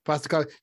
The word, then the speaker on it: Postecoglou: Postecoglou.